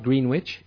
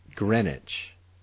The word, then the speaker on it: Greenwich: Watch the pronunciation. It's not Greenwich, it's Greenwich.